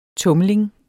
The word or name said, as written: tumling